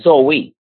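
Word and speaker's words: zoe (ζωή): The Greek word here for life is zoe. It's pronounced zoe. It's spelled Z-O-E.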